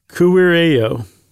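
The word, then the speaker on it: kuirejo: You also have A-R-O, which is a group, like vorto, V-O-R-T-O is a word. Vortaro is dictionary. It just makes a lot of sense. E-J-O, and the J's are pronounced as a Y. Isn't that right? – Yep. – E-J-O is a place for something. So K-U-I-R-I, how would you pronounce that? – Kuireo.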